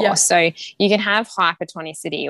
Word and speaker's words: Hypertonicity: Hypertonicity? – Yes, yep, that's it. Hypertonicity.